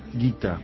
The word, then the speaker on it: Gita: Bueno, Modalidades, según el Bhagavad Gita, es o Gita. ¿Cómo se dice? – Gita.